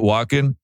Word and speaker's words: Walken: how you say it, Walken? – Yeah.